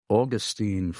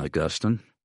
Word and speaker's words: Augustine: And let's see, we have Teresa in St. Augustine, Florida. I believe that's how they pronounce it in Florida, rather than Augustine.